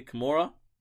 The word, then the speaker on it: Kimura: First off, bad news, sudden death, suicide of AEW's wrestler Hannah, I'm saying it correctly, Kimura? – Yeah.